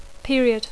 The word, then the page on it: period: English